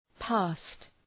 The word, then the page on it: past: pæst